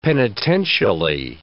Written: penitentially

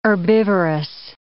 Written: herbivorous

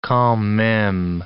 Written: quand meme

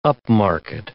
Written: upmarket

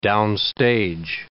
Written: downstage

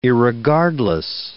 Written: irregardless

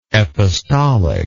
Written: epistolic

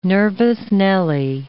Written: nervous Nelly

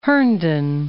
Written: Herndon

